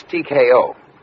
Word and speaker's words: TKO: Williams Bags Crown by Taco in Eighth. – Taco? – Mm-hmm. – That's TKO. – Well, how would you pronounce it? – Technical knockout.